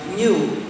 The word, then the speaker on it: GNU: Se usa en muchísimos juegos de palabras, porque según el diccionario la G muda y la palabra se pronuncia new o new, como nuevo, es decir, nuevo.